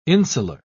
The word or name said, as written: insular